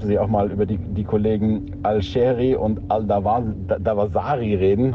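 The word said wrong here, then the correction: Al-Dawsari: Habe ich die jetzt richtig ausgesprochen? – Nee, Al-Dafsari.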